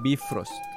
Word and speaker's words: Bifrost: Bifrost, ¿no? – Bifrost.